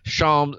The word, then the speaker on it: Shams: Shams. It rhymes with bombs.